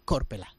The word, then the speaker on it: Korpela: y que todas las palabras en finés se acentúan en la primera sílaba. Pone, por ejemplo, el nombre de Korpela, que en este caso sería Korpela.